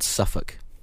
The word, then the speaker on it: Suffolk: It's called Suffolk. We don't pronounce all the letters here.